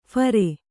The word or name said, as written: ಫೇರಿ